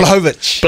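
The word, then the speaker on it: Błachowicz: Blachowicz.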